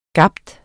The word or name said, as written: gabe